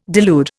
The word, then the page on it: delude: Indian